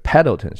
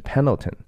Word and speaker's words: Pendleton: 这这个这是一个牛仔的一个皮带扣哈，嗯，他写的 Pendleton 这个 D 是不发音的哈，在这个地名里头，所以它不是 Pendleton 是 Pendleton，底下写着。